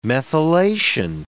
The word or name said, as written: methylation